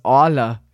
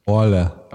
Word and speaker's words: Aalen: also aus Orla, wie man hier sagen würde. – Orla.